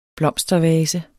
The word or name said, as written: blomstervase